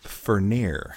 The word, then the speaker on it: Furnier: Mr. Fournier? – Vincent Furnier.